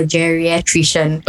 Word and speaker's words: geriatrician: Then it's a Montessori kindergarten, so the teacher made them clap out the syllables, like clap it out. It was like geriatrician.